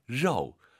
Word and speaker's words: 肉: 고기 발음 로우 아니고 로도 아니고 로우. 로우 기억하시고요.